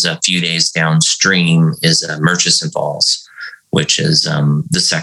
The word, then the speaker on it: Murchison Falls: Yeah, Murchison Falls.